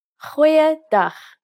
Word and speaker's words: goeie dag: Goeie dag. Aangename kennis. Let's try that again. G. Try pronouncing it from the back of your throat.